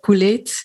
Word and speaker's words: Couleet: Ik ben Lisa Couleet. Veel mensen vroegen zich waarschijnlijk al af hoe je mijn naam moet uitspreken, maar bij deze Couleet.